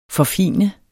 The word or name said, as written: forfine